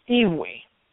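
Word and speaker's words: Eevee: The if I remember, she was telling me this correctly, that the Japanese pronunciation is Evie.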